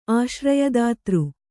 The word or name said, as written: ಆಶ್ರಯದಾತೃ